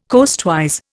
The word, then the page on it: coastwise: Indian